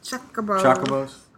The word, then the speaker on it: Chocobos: Chocobos.